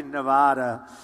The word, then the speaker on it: Nevada: I said, when I came out here, I said, nobody says it the other way. It has to be Nevada, right? And if you don't say it correctly, and it didn't happen to me, but it happened to a friend of mine, he was killed.